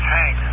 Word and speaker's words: Hank: My name is Hank, Hotel Alpha November Kilo.